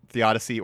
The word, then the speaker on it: theodicy: Is it theodicy? – Yes. – Okay. Yeah, so they call it theodicy, or the problem of evil.